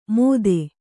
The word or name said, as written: ಮೋದೆ